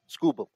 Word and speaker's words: Skubal: Do I pronounce his name correctly? Skubal? – Tarek Skubal. – Skubal, okay.